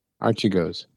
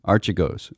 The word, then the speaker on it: Archegos: I have been saying Archegos, which seems quite appropriate in that their egos seemed to be pretty arch until they collapsed.